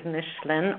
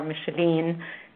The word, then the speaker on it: Micheline: And by the way, I created some confusion because I gave you two pronunciations for my name. My name is Micheline or Micheline Nader, and I was the one who confused you in the beginning.